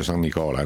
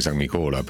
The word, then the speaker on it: San Nicola: Non ha intenzione, pare, almeno ufficialmente, di ribattere nulla, nemmeno allo stadio San Nicola, anzi San Nicola, per rispettare la pronuncia di Bari,